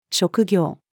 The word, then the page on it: 職業: female